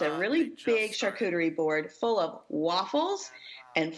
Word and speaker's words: charcuterie: looking for new ideas for charcuterie boards. – Oh, yes. – Sherry was really good about explaining the whole, how it's pronounced, which is S-H-A-R-C-O-O-T-E-R-I-E, charcuterie, because a lot of people mess that up.